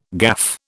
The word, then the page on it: gaffe: British English